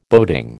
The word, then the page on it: boating: American English